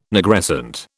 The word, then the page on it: Nigrescent: British English